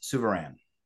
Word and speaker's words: Souverain: Order of Martinist Sovereigns. Is that right? – Close? Good enough. Close. Order of Martinist Souverain.